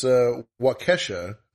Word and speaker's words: Waukesha: Like for this Waukesha is how it's pronounced.